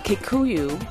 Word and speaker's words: Kikuyu: Kikuyu, am I saying that right? – Yes, that's right.